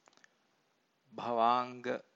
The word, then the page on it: bhavanga: Pali